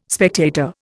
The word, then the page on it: spectator: Indian